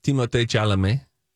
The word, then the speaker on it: Timothée Chalamet: Frankly, his name is pronounced Timothée Chalamet, and that's what the internet told us like a year ago, and so we're choosing to honor that.